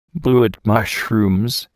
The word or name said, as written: blewit mushrooms